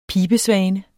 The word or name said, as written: pibesvane